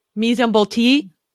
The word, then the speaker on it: mise en bouteille: Mise en bouteille.